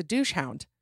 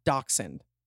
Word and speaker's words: dachshund: And she said, nope, I think it's a douche hound. It's hard to hold in my lap. Homegirl was committed to it. So you got to admire that, right? So yeah, the dog that's spelled dachshund, like a wiener dog. That is pronounced dachshund. I don't even know if I'm saying it right, but I have called it.